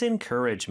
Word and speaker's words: encourage: 그리고 encourage는 여기 부분에 강세가 있습니다. 그래서 encourage라는 거. 마지막 G 발음도요. 물론 여기는 G라고 쓰여져 있긴 하지만 약간 프랑스어 발음처럼 Z. 이렇게 좀 네, 고급스럽게 발음해 주시면 좋을 것 같아요.